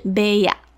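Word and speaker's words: 部屋: 那有些相扑的布屋有开放让观光客去参观，这个布屋呢并不是中文哦，它是从日文的汉字来的，所以日文的发音是 beya，那意思就是房间。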